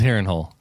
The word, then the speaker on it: Harrenhal: So let's start, with Ghost of Harrenhal. First of all, am I pronouncing that right? – Yes, it is Harrenhal.